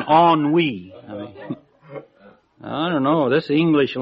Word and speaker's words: ennui: Well, along the same line, there's a word I come across a lot, and until I looked it up, I called it N-U-I, because that's where it's spelled, E-N-N-U-I, N-U-I. But no, it's pronounced ennui. I found that out, ennui.